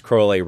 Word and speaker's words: Crowley: So he tells us how to pronounce his name. – That's true. Yeah. And if you get the Sex and Rockets, the new edition, it's got an opening by Robert Anton Wilson, and he keeps reminding you throughout the essay, it's pronounced Crowley, rhymes with holy.